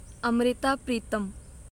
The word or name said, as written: Amrita Pritam